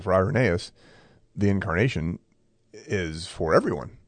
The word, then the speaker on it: Irenaeus: I mean, it's pronounced Irenaeus, but you would read it and say Irenaeus.